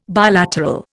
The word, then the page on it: bilateral: Indian